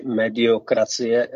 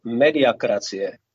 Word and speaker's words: mediakracie: Často se tato chyba vyskytuje, to není jenom jeho chyba, ale slovo mediokracie je špatně vysloveno. V tomto případě totiž mediokracie znamená vláda průměrných. Ale jestli hovoříme o vládě médií, tak je to mediakracie.